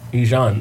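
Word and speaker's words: Ejon: My middle name is Ijon. – Ijon? – Ijon. – How do you spell it? It's like Dijon without the D.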